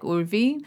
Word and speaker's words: Urvi: And back then in India, you actually pronounce my name like Urvi, but no one can say it here. So she's like, Urvi. And I just hate that. It sounds like a dog barking.